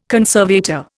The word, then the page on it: conservator: Indian